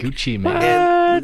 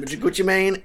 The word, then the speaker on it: Gucci Mane: Like Gucci Mane. – Gucci Mane.